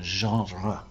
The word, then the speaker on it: genre: New staff member, and I wanted him to get to know Demystifying the Genre, the French pronunciation, genre.